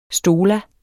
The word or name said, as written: stola